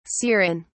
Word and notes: serein: British English

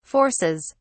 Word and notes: forces: British English

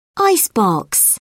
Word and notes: icebox: British English

